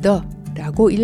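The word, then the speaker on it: ye: 이러한 연유로 현대 영어의 옛날식 글자에서 종종 보이는 ye라는 단어는 예가 아닌 the라고 읽어야 하는 것입니다.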